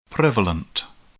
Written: prevalent

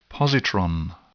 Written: positron

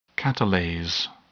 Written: catalase

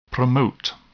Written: promote

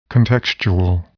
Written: contextual

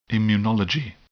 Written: immunology

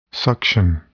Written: suction

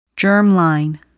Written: germline

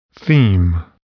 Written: theme

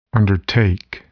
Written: undertake

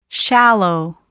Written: shallow

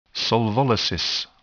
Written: solvolysis

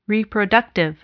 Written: reproductive

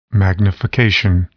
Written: magnification

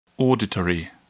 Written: auditory

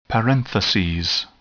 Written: parentheses

